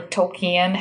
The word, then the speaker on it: Tolkien: Tolkien people or Tolkien, however you're supposed to pronounce Tolkien.